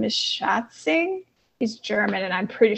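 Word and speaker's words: Schätzing: Nah, it's Schatzing, and you got it. I'm German, and you pronounced that correctly.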